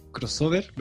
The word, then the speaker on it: crossover: Lo que pasa es que hoy día vamos a hacer una suerte de crossover.